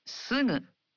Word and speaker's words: すぐ: It's sugu, a voiced nasal. In Japanese, you pronounce gu in the initial syllables of words as a voiced gu, like ga, gi, gu, ge, go. But for other syllables, such as sugu, you pronounce the gu sound as a nasal.